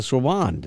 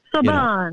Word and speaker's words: Sorbonne: even studied at the Sorbonne. I didn't say that correctly. Yeah. I didn't give it the right inflection, but Sorbonne.